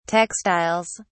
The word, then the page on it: textiles: British English